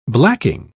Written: blacking

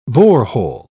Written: borehole

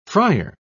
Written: fryer

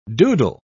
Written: doodle

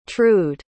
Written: trued

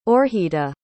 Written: oorjita

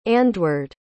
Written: andwearde

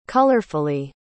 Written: colorfully

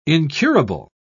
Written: incurable

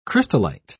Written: crystallite

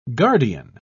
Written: guardian

